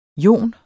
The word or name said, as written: joner